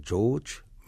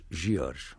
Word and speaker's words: George: de forma que escrevemos da forma inglesa, George, mas pronunciamos uh, em português, George. – Então é a forma correta de dizer não é Francisco George, mas sim não, seria, Francisco seria, George. Seria George.